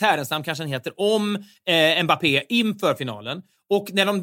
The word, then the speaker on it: Mbappé: Han sitter i studion, tar emot och korrigerar deras uttal och säger då, Mbappé är det korrekta uttalet, eller något liknande.